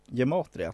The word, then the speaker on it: gematria: de tror starkt på gemat gematria tror jag det uttalas. – Gematria.